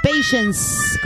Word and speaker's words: patience: Patience. Con S al final, patience.